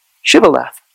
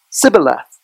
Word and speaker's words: Shibboleth: You had to say Shibboleth, not Sibboleth.